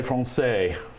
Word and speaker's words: français: Ballet français.